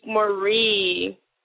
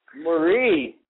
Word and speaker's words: Moree: And y'all said my name, my last name wrong. – It is Monique Marie. Marie. – Everyone oh, always says Monique Marie. It does sound sexy, Monique Marie. but it's Monique Marie.